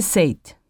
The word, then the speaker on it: Sait: Mrs. Sait. – Can you spell that, please? – S-A-I-T.